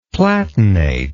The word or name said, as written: platinate